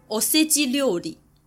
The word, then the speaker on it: おせち料理: 大年初一到大年初三左右呢，日本人会习惯吃所谓的年节料理，日文念作おせち料理，汉字就写作御节料理，御驾亲征的御，节日的节。